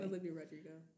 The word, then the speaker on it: Olivia Rodrigo: Is that how you pronounce it? – Yeah. Okay. Olivia Rodrigo.